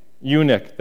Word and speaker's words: eunuch: In Acts 8, 35 and 38, this is when Philip is talking to the Enoch. Is that what it is? Enoch? Yeah. Eunuch, that's it. Thank you. I know I was saying it wrong.